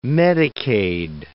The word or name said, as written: Medicaid